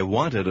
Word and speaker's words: wanted: I wanted a beer. Wanted. – Вы вспомнили, что здесь ed произносится как два звука.